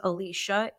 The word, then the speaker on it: Alisha: Alisha B. Forrester Scott. And if you just typed in Alisha B, my first name is spelled a little different than other Alicias. My name is Alisha, A-L-I-S-H-A. Alisha B, like the insect, B-E-E.